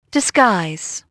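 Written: disguise

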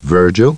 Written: Virgil

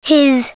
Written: his

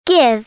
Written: give

